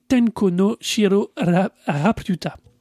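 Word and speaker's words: Tenkū no Shiro Rapyuta: C'est la piuta, non Alors non, en japonais c'est pas la piuta. Bon d'accord, tu me forces. Je vais donc le prononcer. Le titre japonais du film est Tenko no Shiro Raputa.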